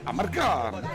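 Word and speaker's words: Amarcord: Però in realtà la pronuncia esatta sarebbe a Marcord, mi ricordo, no, di quella cosa.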